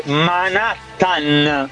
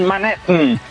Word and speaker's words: Manhattan: Jr. Jackson Spagno Spannaus che si dice Manhattan, non Manhattan. Ho sentito il podcast. Che nervi, Manhattan! – Perché, perché deriva dai nativi americani, questo ce l'ha spiegato, quindi bisognerebbe pronunciarla. – Alla, però.